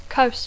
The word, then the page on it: coast: English